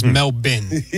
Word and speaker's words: Melbourne: The Australian pronunciation, Sal, is Melbourne.